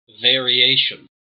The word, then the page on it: variation: American English